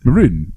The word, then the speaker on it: maroon: everyone says wrong in Australia. Maroon. It's maroon. – It's maroon. – No, are you saying it right? – I'm saying it right. It's definitely maroon. Maroon.